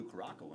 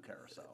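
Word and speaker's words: Curaçao: But I always thought it was Blue Curaco when I was a kid. Should be Blue Curacao.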